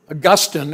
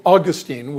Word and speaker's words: Augustine: Augustine, or as I tend to pronounce his name, Augustine, was one of the great theologians of church history.